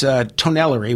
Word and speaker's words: Tonnellerie: So it's Tonnellery, which is T-O-N-N-E-L-L-E-R-I-E.